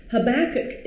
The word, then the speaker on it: Habakkuk: And please forgive me if I do not pronounce Habakkuk. Right. I will try to get that K on the end.